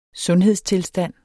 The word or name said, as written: sundhedstilstand